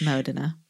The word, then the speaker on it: Modena: I know, but I said Modena, like the Tone Loc song, Funky Cold Modena. – Oh. – Like, I pronounced it wrong. – Oh, yeah. – It's embarrassing. – It's Modena.